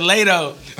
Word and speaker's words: Latto: I thought it was Lotto. Oh, okay. – My nigga, man. My nigga said Lato. Like Plato.